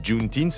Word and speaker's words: Juneteenth: Alors, de quoi s'agit-il Juneteenth Ça s'écrit June comme le mois de juin, Teens, T-E-E-N-T-H.